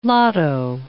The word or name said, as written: lotto